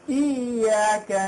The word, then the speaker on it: إياك: cái chữ iyya iyya chúng ta phải đọc chữ y nó nặng một chút ờ, à, nó phải là iyya bình thường mà iyyaka